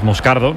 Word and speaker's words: Moscardo: Entonces, sí, como, como bien ha pronunciado Unai, es Moscardo, no es Moscardo,